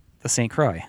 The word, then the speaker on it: St. Croix: The St. Croix. – Yeah, the St. Croix. – The St. Croix. Cool. All right. That's good. That's good. You actually pronounce it sort of French.